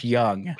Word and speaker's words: Jung: It's a soft J, by the way. – Josh Young.